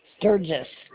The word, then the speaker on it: Sturgis: Paula Sturgis. – Will you spell that last name? – S-T-U-G-I-S. S-T-U-R-G-I-S.